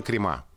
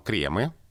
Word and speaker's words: кремы: А, крем и крема. Вот сейчас это вариант, который э, тоже обсуждается. Литературная норма кремы. Крема пока говорить не, не рекомендуется, но это ударение крайне распространено в речи.